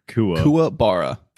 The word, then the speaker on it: Kuwabara: I loved how Kuwabara, I'm pronouncing that right, correct? – No. – Karubane? – Kuwabara. – Kuwabara. God damn it.